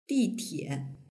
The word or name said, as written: ditie